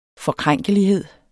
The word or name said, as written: forkrænkelighed